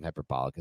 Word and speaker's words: hyperbolic: I don't think hyperbolic is the way to pronounce that word. But anyway. – Well, hyperbole is how to pronounce it, but in a different form, it would be hyperbolic.